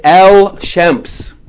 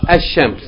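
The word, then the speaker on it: Ash-Shams (الشمس): You're not going to say Al-Shams. You're going to say Ash-Shams.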